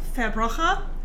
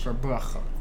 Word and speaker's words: Verbrugge: It's actually pronounced Verbrugge, if you're going to say it in Dutch. – Verbrugge.